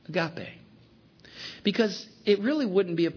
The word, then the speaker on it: agape: There is no g sound, I guess, so it's a little softer, agape.